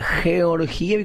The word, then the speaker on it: Georgieva: Bueno, acá hay un oyente que nos dice en el YouTube, en el chat de YouTube, señores comunicadores, como el nombre es de origen eslavo, se pronuncia Georgieva.